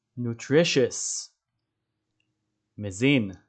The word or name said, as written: nutritious